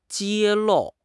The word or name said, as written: 揭露